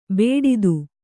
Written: ಬೇಡಿತು

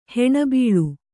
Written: ಹೆಣಬೀಳು

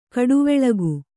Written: ಘಟ್ಟಿವಾಳಿಕೆ

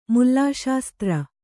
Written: ಮುಲ್ಲಾಶಾಸ್ತ್ರ

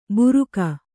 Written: ಬುರುಕ

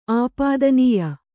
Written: ಆಪಾದನೀಯ

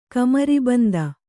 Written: ಕಮರಬಂದು

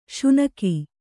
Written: ಸೀನ್ಕೆ

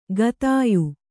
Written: ಖಾದ್ಯ

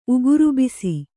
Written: ಉಗುರುಬಿಸಿ

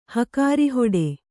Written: ಹಕಾರಿಹೊಡೆ

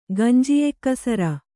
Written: ಗಂಜಿಯೆಕ್ಕಸರ

